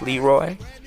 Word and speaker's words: Leroy: You remember me, Keith Leroy? Sure I do. Last name accented on the first syllable.